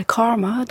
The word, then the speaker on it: CCRMA: qui s'appelle Karma, donc ça s'écrit CCRMA. Mhm.